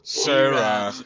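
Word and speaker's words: Zara: No, güey, no se dice Sara, se dice. – Sara. Sara.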